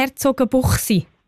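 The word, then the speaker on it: Herzogenbuchsee: Habe ich es eigentlich richtig gesagt? Herzogenbuchsi. – Ist genau, gut so? Ja, das ist so.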